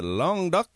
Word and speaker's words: Languedoc: we had Cyril Frechier talking about the Languedoc, or for you Americans, Languedoc. You know, either way gets you where you want to go. But Languedoc is the preferred French pronunciation.